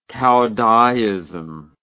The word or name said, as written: caodaism